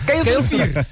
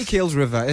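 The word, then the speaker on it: Kuilsrivier: It's Kaelsrivier. Huh? Don't say Kaelsriver. It's Kaelsrivier.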